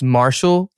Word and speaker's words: MRSHLL: His name is Marshall, and he stylizes it, spells it as M-R-S-H-L-L. So it's Marshall, like the way you would spell it, without any A's, right? Marshall with no A's. M-R-S-H-L-L.